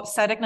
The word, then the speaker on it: Sadec: So Sadec 1965 is a solo storytelling show and it's on a true story.